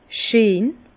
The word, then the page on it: scheen: Bas-Rhin; Herrlisheim